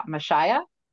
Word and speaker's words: Mashiach: And then we'll go on to the second one, which is called the Mashiach. – Mashiach. – Mashiach. Okay, perfect.